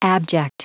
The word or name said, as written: abject